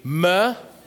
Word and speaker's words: Mephibosheth: Repeat after me. Mephibosheth. So it's like Seth, but with a sh instead of, right?